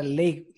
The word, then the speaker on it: Leigh: Pues mira, me estoy leyendo unas novelas muy chulas de fantasía eh, de una mujer que se llama Leif, Leif Barduco, se escribe L-E-I-G-H.